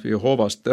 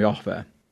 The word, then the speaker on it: Jahve: aga tõenäoliselt selle neljatähelise nime hääldus ei olnud Jehova. Et ikkagi tänapäeval usutakse praktiliselt eranditult selle valdkonna siis nagu keele ja ajaloo ja teoloogia spetsialistide poolt seda, et see oli pigem Jahve.